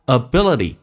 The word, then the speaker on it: ability: ability 这个单词 ability，记住 t 要浊化成 d。